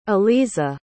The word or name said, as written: aliza